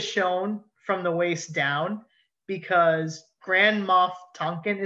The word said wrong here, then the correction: Tarkin: is that how you say his name? Tarkin. Thank you, Tarkin.